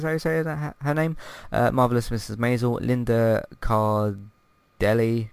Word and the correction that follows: Cardellini: Car- Cardellini. Cardellini, that's it.